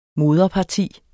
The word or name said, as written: moderparti